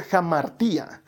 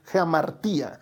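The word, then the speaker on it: hamartía: Mira, una palabra griega que aparece mucho en la Biblia es la palabra pecado, que en griego se pronuncia jamartía, jamartía. Literalmente significa errar en el blanco.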